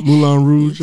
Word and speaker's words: Moulin Rouge: Yes, so we went to Paris, and we stayed in the area near the Moulin Rouge. Is that how you say it? – Right. – Okay. – Moulin Rouge.